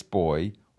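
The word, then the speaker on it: B'y: Most popular is yes, boy, or go on. Is that, I can't work that out. – Yeah, it is. – It's spelled B apostrophe Y, but it is pronounced boy.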